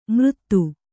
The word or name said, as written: ಮೃದು